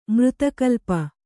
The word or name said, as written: ಮೃತಕಲ್ಪ